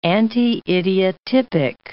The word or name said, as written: anti-idiotypic